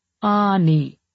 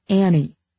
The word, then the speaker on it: Ani: "Her name is Ani, and it's pronounced Ani, not Annie.